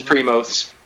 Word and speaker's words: Primos: Let's wind down with how badly Mr. Jason, the Kingpin, Mr. Colorado Springs Wrestling, is going to destroy a motherfucker at Primos? Is that how you pronounce it? I'm not sure. – It's Primos.